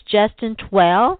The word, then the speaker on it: Justin Twell: It's Justin Twell.